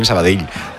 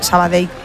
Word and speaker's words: Sabadell: El cachondo de Kiki Lisa me dice, pronuncias muy bien Sabadell. Dice, mis respetos. – A mí me lo decían mucho cuando estaba en Primera Federación, porque yo también decía Sabadell, que es como se dice.